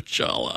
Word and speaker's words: challah: It looks like a loaf of challah. – And before anyone sends anything in, yes, you do pronounce the C and the H.